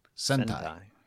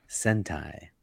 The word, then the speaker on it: Sentai: How do you say this one? – Sentai. – Sentai.